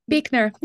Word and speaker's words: Buechner: Is it Buchner? – Buechner. Yeah, Buechner. Weirdly, it's pronounced Buechner.